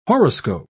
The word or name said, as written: horoscope